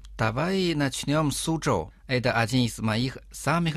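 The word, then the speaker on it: Су-чжоу (苏州): «Су» читается в первом тоне, «чжоу» тоже в первом тоне. Повторите за мной. «Су-чжоу».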